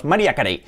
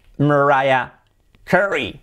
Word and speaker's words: Mariah Carey: Pues la pronunciación correcta sería Mariah Carey. Mariah Carey. Yes, Mariah. María se pronunciaría así. Mariah. Raya. La I es en el formato II, so Mariah. Y la A primera es un sonido muy fuerte contaminado con la R posterior, con lo que sería Mariah.